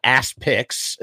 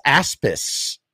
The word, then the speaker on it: aspics: I think this is, it's not Aspix. It's not Aspix. I think it's Aspis. Aspis? I believe that's the phonetic pronunciation.